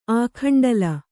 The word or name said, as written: ಆಖಂಡಲ